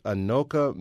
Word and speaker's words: Anoka: Let's go on the line right now and talk to John from, I think it's pronounced Anoka, Minnesota. Is that right, John? – Yes, it is.